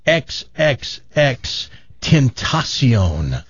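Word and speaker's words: XXXTentacion: I found out the correct pronunciation of his name too. It's XXX. Tentacion.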